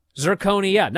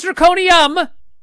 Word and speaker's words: zirconia: Cubic zirconia, not zirconium. Zirconia.